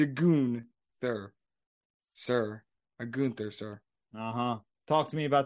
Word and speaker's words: Gunther: I appreciate you uh, saying his name correctly. As Gunther. Gunther. – Thank you. Apparently, Ted doesn't understand that it, you have to have a goon and then a thur.